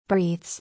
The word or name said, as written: Breathes